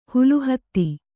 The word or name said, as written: ಹುಲುಹತ್ತಿ